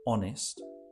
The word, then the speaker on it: honest: Um, and one of the options there was the word honest. Just as a little pronunciation there, the H is silent you want to help with that one? Honest. Honest. Okay, so we just kind of leave the H out.